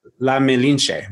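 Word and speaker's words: La Malinche: La Melinche. Is that how oh, you oh, yes, pronounce yes, it? Yes. Mm-hmm.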